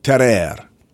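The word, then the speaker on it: Tarrare: Today I'm going to tell you about Terer. Terer, terer. It's a French word. I'm trying to pronounce it authentically.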